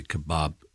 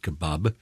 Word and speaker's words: kebab: And so we've put an A because we want to say kebab, kebab, which is how they say it, kebab. And an American would say, spell kebab, K-E-B-O-B.